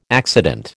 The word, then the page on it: accident: American English